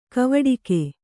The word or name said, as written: ಕವಡುಗ